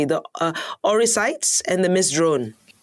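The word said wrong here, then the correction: Oryctes: Yep, it's actually named Orictus. It's a scientific name of one of the pests.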